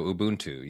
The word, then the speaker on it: Ubuntu: But Ubuntu, am I saying that right? – Yeah, as far as I know, Ubuntu, yes. That's how I've always heard it pronounced, yeah.